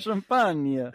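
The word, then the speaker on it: Champagnie: So Champagne is how you say their name.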